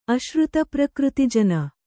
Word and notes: ಅಶ್ರುತಪ್ರಕೃತಿಜನ: kannada

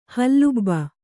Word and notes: ಹಲುಬು: Kannada

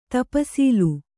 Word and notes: ತಪ್ಸೀಲು: tapsīlu

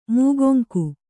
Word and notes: ಮುಕ್ಕೊಂಗು: mukkongu

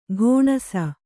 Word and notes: ಘೋಣಸ: ghōṇasa

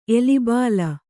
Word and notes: ಎಲಿಬಾಲ: kannada; elibāla

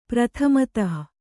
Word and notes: ಪ್ರತಿಮಾತು: prati mātu

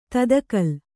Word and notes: ತದುಕಿಲು: Kannada

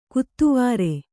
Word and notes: ಕೆತ್ವಾರಿ: ketvāri

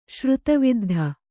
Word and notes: ಶ್ರುತಿವಿದ್ಧ: Kannada

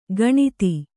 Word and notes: ಗಣಿತ: kannada

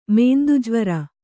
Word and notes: ಮೇಂದುಜ್ವರ: mēndu jvara

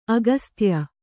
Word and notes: ಅಗಸ್ತ್ಯ: agastya

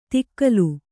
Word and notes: ತಿಕ್ಕಲು: tikkalu; Kannada

